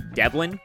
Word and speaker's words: Devlin: And apologies to Delvin Hodges because his name is actually Devlin. Right? We both said that wrong, Connor.